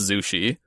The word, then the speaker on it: Zushi: I think this is the case, though, that his name might actually use the old characters for the word sushi, which, without being in compound words, was just pronounced Zushi.